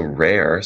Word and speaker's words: Reyr: It's R-E-Y-R. – Yeah, R-E-Y-R. So rare sounds like the word unique, you know, rare, R-A-R-E.